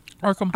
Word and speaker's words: Arkham: Is it Arkham? Arkham? – Arkham.